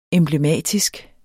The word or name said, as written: emblematisk